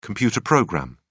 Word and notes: computer program: British English

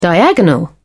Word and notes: diagonal: British English

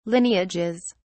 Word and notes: lineages: British English

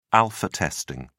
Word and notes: alpha testing: British English

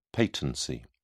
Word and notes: patency: British English

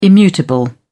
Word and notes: immutable: British English

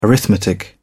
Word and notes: arithmetic: British English

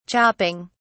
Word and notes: chopping: British English

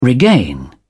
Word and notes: regain: British English

